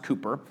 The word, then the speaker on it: Cowper: A guy named William Cooper, his name is spelled C-O-W-P-E-R, it's pronounced Cooper.